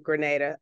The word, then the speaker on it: Grenada: And I've seen pictures of Grenada. Oh, did I say it right? – You did again, Okay.